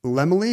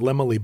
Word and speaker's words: Laemmle: not obvious how to pronounce it exactly. It's spelled L-A-E-M-M-L-E. – I've always assumed it's Lemely, because in old Hollywood, they would say Carl Lemely has a very large family.